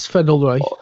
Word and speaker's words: Sven Ulreich: We all know in the Bernabeu as well, they was somewhat fortunate with, is it Sven Ulreich? I think is how you pronounce it. – Sven Ulreich.